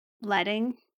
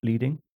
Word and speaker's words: leading: Yeah, I think the reason leading has a space in my head is because I suspect a lot of people assume it means leading, or assume it's pronounced leading.